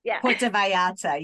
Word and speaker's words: Vallarta: Puerto Vallarta, yeah. It's actually, Vallarta is because the two Vallarta. L's is with a ya. They pronounce a double Vallarta. L as a ya, so it's Vallarta.